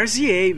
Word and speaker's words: RZA: E não houve como lembrar da discussão desse podcast, pois esse filme escrito pelo Raz ah, é RZA mesmo. – RZA? É, sigla, é RZA. Não tem uma pronúncia, não.